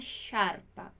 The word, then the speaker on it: sciarpa: Na pronúncia dessa palavra sciarpa, a gente diz assim. Sciarpa. Não é o mesmo som? Se você ler essa palavra aqui escrita com CH, você vai dizer sciarpa.